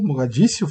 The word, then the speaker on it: Mogadíscio: um filme que fala sobre a Batalha de Mogadíscio, Mogadíscio, falei certo, Jair? Isso aí.